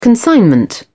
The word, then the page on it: consignment: British English